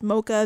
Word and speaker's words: MXKA: That particular artist's name is spelled M-X-K-A, but pronounced Mocha.